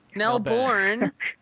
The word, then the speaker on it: Melbourne: Melbourne. – No, it's Melbourne. – Melbourne. Yeah, not born.